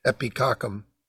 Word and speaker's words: Epicoccum: Believe it or not, that's how it is. It's pronounced epicoccum.